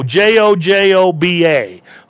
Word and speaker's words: jojoba: Another thing that helps, there's a uh, an oil called jojoba, J O ho ho, J O J O B A, jojoba. You don't pronounce the J, and that's an oil that has been found to regrow hair.